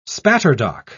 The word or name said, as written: spatterdock